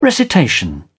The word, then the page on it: recitation: British English